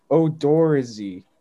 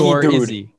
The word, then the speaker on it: Odorizzi: It says Odorizzi. – Yeah, sound it out. – That's stinky Odorizzi. Odorizzi.